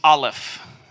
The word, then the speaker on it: Aleph: So the first character, or the last character, if you're looking in English, is Aleph. That letter has no pronunciation. The way that they say is like, it's like the sound that you make right before you say the A in apple.